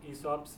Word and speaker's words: Aesop: I guess it's pronounced Aesop. – Oh, right, of course, Aesop, of course, with the talking animals.